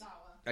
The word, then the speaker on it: Tozawa: Tozawa. Did I say that right? – Akira yes. Tozawa.